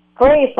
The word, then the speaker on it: Grace: Z like girl, R-A-C-E. – Oh. Shriek. – Greece. – Greece. – Grace, like a beracha, grace. – Grace. Now I got you. Yeah. G-R-A-C-E.